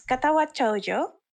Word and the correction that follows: Katawa Shoujo: Okay. – Katawa Shoujo. Okay.